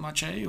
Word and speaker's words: Maciej: I say, just remember, it doesn't take much, eh? That's so cool. – Yeah, it's like, you know, people say my name all the time, but they don't actually realize it. You know, they say, oh, that's not much A eh?